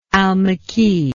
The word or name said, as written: almique